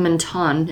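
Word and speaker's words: Menton: Yeah, the Sciences Po campus for the Middle Eastern studies is in Mentone.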